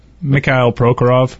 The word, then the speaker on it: Mikhail Prokhorov: We'll get to some Mikhail, is that how you pronounce his name, Prokhorov, or is it Mikhail? – Mikhail Prokhorov.